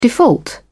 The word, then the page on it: default: British English